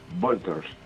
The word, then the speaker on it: Vultures: ¿Cómo lo pronunciaría bien? – Vultures.